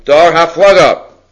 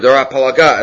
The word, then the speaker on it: Dor HaFlaga: Dor HaFlaga, or Dor HaPalaga, as some pronounce it, Ein lehem chelek olam haba.